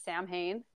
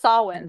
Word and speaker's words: Samhain: So it has its roots in the ancient Celtic festival of Samhain, which I did not know how that was pronounced until I literally researched this, because it's spelled S-A-M-H-A-I-N. – Yep. – So I always pronounce it like Samhain. – Yeah. – But it's Samhain.